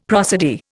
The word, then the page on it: prosody: Indian